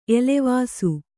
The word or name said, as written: ಎಲೆವಾಸು